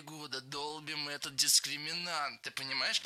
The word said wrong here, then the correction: долбим: Не долбим, а долбим. Ударение в этом глаголе всегда стоит за корнем. Долбишь, долбит, долбим, долбят. Во всех формах ударяем окончание.